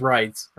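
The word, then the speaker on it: Reitz: I R-E-I-T-Z, I think it's, if it's German, it's pronounced writes, right?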